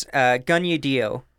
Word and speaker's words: Kaniehtiio: Like, it's intense, but it's Gunye Dio Horn, and she was 4. – How do you spell it? – K-A-N-I-E-H-T-I-I-O.